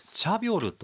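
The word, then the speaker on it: チ ャ ビ ョ ル (차별): 差別と書いてチャビョルと読む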